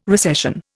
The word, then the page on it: recession: Indian